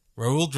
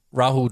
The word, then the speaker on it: Rahul: Is it A, Raul Dravid? – Oh, that's awful. – The wall. – It's Rahul Dravid.